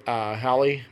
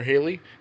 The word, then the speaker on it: Halle: I guess Chloe X Halle or Haley. it's spelled like Halle Berry.